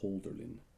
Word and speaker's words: Hölderlin: I don't know if anyone out there has ever heard of Hölderlin, but or I should say his proper pronunciation is Hölderlin.